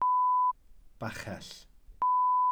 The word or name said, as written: bachell